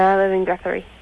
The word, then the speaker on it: Guthrie: Guthrie.